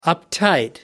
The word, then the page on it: uptight: American English